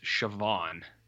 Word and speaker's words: Siobhan: I believe this person's name is pronounced Siobhan. – Oh. – And the only reason I know that, Jared, is because of Succession. Because there's a character named Siobhan, and it's spelt like this.